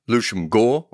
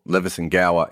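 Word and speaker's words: Leveson-Gower: South Africa by Shrimp Lucian Gore, or Levison Gower if you're reading it phonetically, and a gold tycoon called Abe Bailey.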